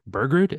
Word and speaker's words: Bergrud: I have with me my co-host, Eric Bergrud.